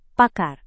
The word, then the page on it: pakar: Indonesian